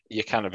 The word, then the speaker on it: Jokanović: Jukanović, that's how you pronounce it.